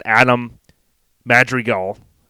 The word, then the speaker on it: Adam Madrigal: We got Adam Madrigal.